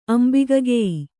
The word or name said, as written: ಅಂಬಿಗಗೆಯಿ